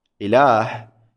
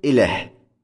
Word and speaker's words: إله: المثال الثالث، إله، إله تلفظ بهمزة ثم لام ثم ألف ثم هاء، لكنها تكتب إله، إله من دون ألف.